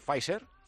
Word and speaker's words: Pfizer: ¿Lo he pronunciado bien, Pfizer? – Sí, lo has pronunciado fantástico.